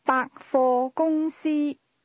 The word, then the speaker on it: 百货公司: This is pronounced with a series of mid-level and high tones. – 讲。 – Department store. – 百货公司。